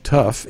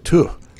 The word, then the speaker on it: Tough: town of Tuff in Scotland. They pronounce it Tuff. Tuff.